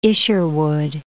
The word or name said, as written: Isherwood